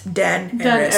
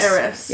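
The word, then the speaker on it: Daenerys: But in the book, it literally says Dan, A-R-E-S, Den Eris. – Den Eris.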